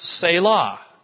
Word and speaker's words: Selah: And it's a Hebrew word that is pronounced Selah.